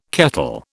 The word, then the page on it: kettle: British English